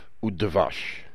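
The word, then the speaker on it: udvash (וּדְבַשׁ): When you say, chalav udvash, you mean milk and honey. The vav there, which is pronounced u because of certain reasons, is a conjunctive vav.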